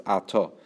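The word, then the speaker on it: ato (אתה): So this ato is written in the way it's written is actually without the hey. It's just written aleph tuf, but it's pronounced ato and not at.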